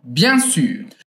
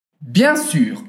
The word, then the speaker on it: bien sûr: Bien sûr. – Bien sûr, en faisant attention à bien prononcer le son U. Bien sûr.